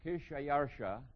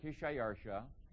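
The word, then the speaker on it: Xšayaršā: It's Kishayarsha, that's the Persian name there, Kishayarsha.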